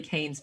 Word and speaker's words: Kean: I actually had the same reaction in reading Surviving Death, which is Leslie Kane's book. And her last name is spelled K-E-A-N.